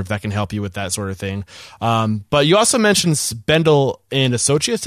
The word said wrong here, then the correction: Sundell: Sundell, S-U-N-D-L-L, yeah.